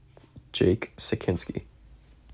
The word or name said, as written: Jake Sekinski